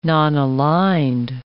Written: nonaligned

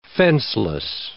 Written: fenceless

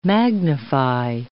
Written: magnify

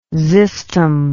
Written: xystum

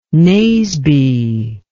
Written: Naseby

